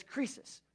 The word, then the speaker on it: Croesus: Now, it's spelled C-R-O-E-U-S or something like that, but it's Croesus.